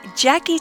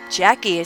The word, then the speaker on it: Jacquee: When on the apps, search for my Jackie T Detours Network and subscribe. Slow down before entering your search. I have a unique spelling for my name. Jackie is spelled J-A-C-Q-U-E-E.